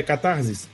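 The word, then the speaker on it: catharsis: É como eu falei, só que com IS no final, tá, gente? Não, é, em grego mesmo, pô. É catarsis. Não, mas em, grego, em grego mesmo?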